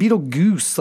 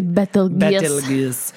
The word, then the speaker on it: Betelgeuse: And then, yeah, it's like this classic, like Vegas, like neon sign, but it's spelled Beetlegoose, like G-E-U-S-E, right? – It's Betelgeuse.